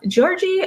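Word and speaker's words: Gyorgy: And he passes away, and in his will, he is basically telling this, I think, cousin of his named Georgie Thurzo. Georgie spelt with a two Y's. G-Y-O-R-G-Y.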